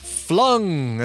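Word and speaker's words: Fluung: Hey, let's go to Seattle for an indie hard rock band called Flung. And I say it like that because there's two U's, F-L-U-U-N-G, Flung.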